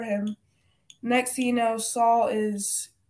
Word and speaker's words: Saul: You said it right, Saul.